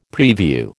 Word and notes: prevue: American English

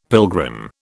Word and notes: pilgrim: British English

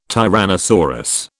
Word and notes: tyrannosaurus: British English

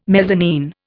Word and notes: mezzanine: Indian

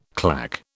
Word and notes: clack: British English

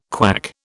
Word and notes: KWAC: British English

